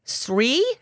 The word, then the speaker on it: Sri: But this is in Sri. Am I saying that right? S-R-I? – Sure.